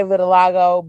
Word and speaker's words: vitiligo: I want to say vitiligo, but I don't know if I'm pronouncing it right.